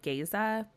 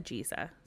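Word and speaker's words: Géza: Because I would have said Geza, but both of them said Giza.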